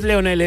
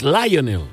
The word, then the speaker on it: Lionel: No es Lionel, es Lionel.